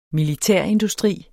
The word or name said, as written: militærindustri